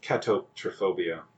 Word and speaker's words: catoptrophobia: It's pronounced catoptrophobia.